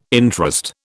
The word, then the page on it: interest: British English